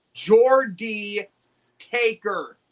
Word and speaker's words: Jordy Taker: I'm about to find a way to, you know, get out of someone's butthole from the light chat if they don't pronounce it right. It's Jordy Taker. JORDYTAKER.